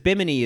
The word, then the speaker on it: Bimini: Bimini, because Bimini is it's an island.